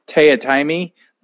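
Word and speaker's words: Teatime: who's like, his name is Taya Timey, but it's spelled tea time.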